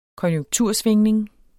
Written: konjunktursvingning

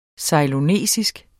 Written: ceylonesisk

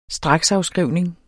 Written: straksafskrivning

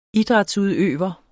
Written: idrætsudøver

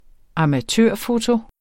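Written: amatørfoto

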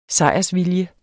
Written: sejrsvilje

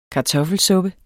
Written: kartoffelsuppen

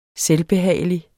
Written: selvbehagelig

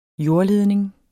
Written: jordledning

